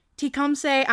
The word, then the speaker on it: Tecumseh: Aubrey Trail is on death row at the Tecumseh, I'm so sorry. I don't know how to pronounce that. If I pronounce that wrong, sorry. Sounds right to me.